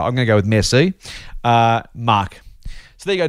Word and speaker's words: merci: He finishes off saying, "Merci," and in brackets, "not mercy, it's pronounced merci."